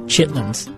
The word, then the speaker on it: chitlins: Have you ever heard of a meal called, I believe it's pronounced chitlins? – Oh, yes. Oh, yeah. I've had chitlins.